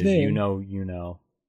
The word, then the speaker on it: Yunho: That it says You Know, capital U-K-N. O-W, and then the... – Yeah, that's his stage name is You Know. – Is Y-U-N-H-O, which sounds like it would be pronounced the same way.